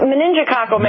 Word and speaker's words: meningococcal: Has there been a study that suggests children of like experience, you get the vaccine, you don't get meningococcal, pronounce it again for me because I keep having a hard time. – It's meningococcal meningitis.